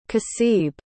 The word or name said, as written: kaseeb